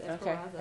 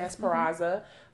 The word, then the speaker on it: Esperanza: And also shout out to the blog in her site, Emily McCrary Ruiz Esperanza. I hope I'm saying that correctly. – I mean, if there's no N, then yes, Esperanza. – Okay. Esperanza. Mm-hmm.